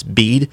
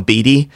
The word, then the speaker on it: Bede: It's by a monk whose name is, I think it's properly pronounced, it's Bede. I always call him Beedy.